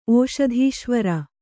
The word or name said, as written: ಓಷಧೀಶ್ವರ